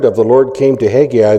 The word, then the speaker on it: Haggai: It is actually pronounced, if you want the line on it in Hebrew, it's Haggai. The last syllable is emphasized. It's not three syllables, it's two, Haggai.